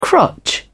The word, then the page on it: crotch: British English